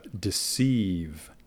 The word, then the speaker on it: deceive: en el principio hubo la palabra deceive, deceive, engañar, deceive.